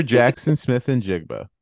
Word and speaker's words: Jaxon Smith-Njigba: Mr. Jaxon Smith-Njigba.